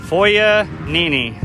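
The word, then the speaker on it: Foyanini: Foya Nini.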